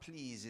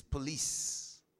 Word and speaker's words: please: And please is not please, it's police. Police.